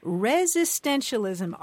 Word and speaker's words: resistentialism: So, would you pronounce that one again for me? – Resistentialism, resistentialism.